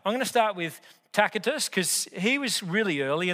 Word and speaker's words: Tacitus: So let's look at Tacitus, that's how you pronounce it, Tacitus.